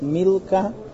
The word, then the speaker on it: Milcah: Letters of Malka, but pronounced Milka.